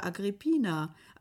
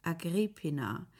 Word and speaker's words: Agrippina: Als sie nach Rom kam, setzte man den Akzent nicht anders als in Köln, auf der dritten Silbe. Das enttäuschte sie. Eleganter hätte sie ihn auf der zweiten Silbe gefunden. Agrippina, wie eine um die Schultern geworfene Stola.